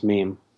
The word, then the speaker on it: meme: So right off the bat, we're pronouncing it meme? – Yeah, that's true. It's comes from an old Greek word, which means to imitate. But yeah, it's pronounced meme.